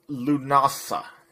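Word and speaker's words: Lughnasadh: Add on to the fun by doing this Luganasa. – It's pronounced Lugnasa.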